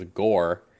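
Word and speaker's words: Gorr: But his retirement is interrupted by a galactic killer known as Gore, which also rhymes with Thor.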